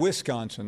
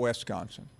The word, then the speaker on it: Wisconsin: Wisconsin. They're gonna. – It's Wisconsin, not Wisconsin. – Wisconsin.